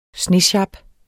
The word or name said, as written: snesjap